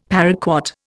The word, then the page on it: paraquat: Indian